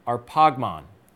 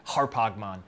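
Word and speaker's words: arpagmon (ἁρπαγμόν): And then down here, "a thing to be grasped" is arpagmon, or if you prefer the Erasmian pronunciation, harpagmon.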